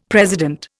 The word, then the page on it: president: Indian